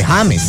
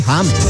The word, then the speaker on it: James: Entonces no se dice James, se dice James. Hay que decirles. – Claro. – Se pronuncia correctamente James.